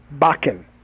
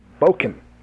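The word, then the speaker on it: bokken: look up the pronunciation. It's not Bakken. It's Boken. – Boken.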